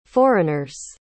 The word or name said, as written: foreigners